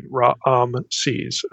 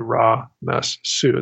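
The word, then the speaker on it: Ramesses: And also there's a whole other syllable that's missing, Ra-am-sees, as opposed to Ra-mes-su.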